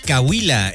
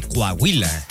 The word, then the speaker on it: Coahuila: Yo conocí a alguien que decía Cahuila en vez de Coahuila.